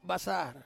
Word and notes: basar: Ansus

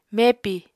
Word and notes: mepi: Apatani

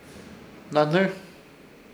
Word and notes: Hnahnɨr: Ishir Chamacoco

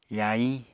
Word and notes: yahiñ: Apatani

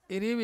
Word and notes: irivi: Ansus

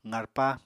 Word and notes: qarpa: apatani

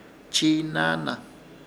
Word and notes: Chiinaana: Ishir Chamacoco

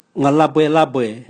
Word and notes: mwalabwelabwe: Raga

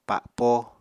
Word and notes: pa7po: Apatani

